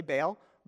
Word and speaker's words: Baal: So the people had eventually started to become really complicit with the prophets of Baal and the worship of Baal, or what we would say Baal. Baal is how kind of, it's a two-syllable word.